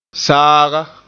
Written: saaga

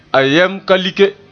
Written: like

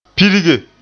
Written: pirge

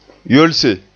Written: yolse

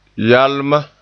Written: yalma

